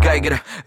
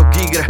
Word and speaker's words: Giger: Vzadu v rohu sedí klasický MHD rider, ksicht vypadá jako když ho navrhoval Geiger. Vím, čte se to Giger, za blbý keci místní Seeger vytáhne tě za jízdy z busu za flígr.